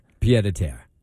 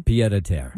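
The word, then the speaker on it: pied-à-terre: Pied a terre. – Say that again? – Pied a terre.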